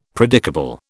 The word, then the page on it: predicable: British English